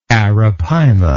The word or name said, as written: arapaima